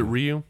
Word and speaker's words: Ryu: Ryu from Street Fighter 2. – It's pronounced Ryu. – Is it Ryu? – Ryu.